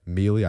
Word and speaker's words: Melee: And yes, I pronounce it Melee. I've been getting criticized by several friends for saying it Melee. I've looked it up. It is appropriate to say it Melee, and I will not say it Melee, regardless of what you guys want me to say.